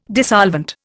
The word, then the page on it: dissolvent: Indian